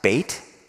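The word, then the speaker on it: bait (בית): In Hebrew, if you see the word B-E-T-H, it looks like the woman's name Beth, right? It's actually pronounced bait, like fishing bait, and it means house of.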